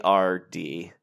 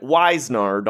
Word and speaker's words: Wizenard: First off, we should mention, you and I, this word, right off the bat, let me spell it for you guys. It's Wizenard. – Mm-hmm. – And I have been reading it Wisenard all day. – Wisenard every time.